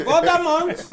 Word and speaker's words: Voldemort: It's a Voldemort.